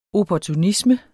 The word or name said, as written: opportunisme